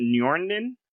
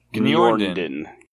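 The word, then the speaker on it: Gneorndin: Gniorndin, you have to pronounce it. – That's Set's son. – Yeah. – Yeah. – You know, you don't have to, I don't think you pronounce the G though, do you? – Well, let's ask the audiobook listeners.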